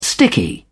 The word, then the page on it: sticky: British English